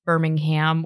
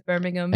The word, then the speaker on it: Birmingham: So I'm from Birmingham, Alabama, which is pronounced Birmingham, which is pronounced wrong from what I'm told by everyone here in the US, because Birmingham. – I guess, but yeah. – Yeah. But they're totally different places, so we just pronounce them differently.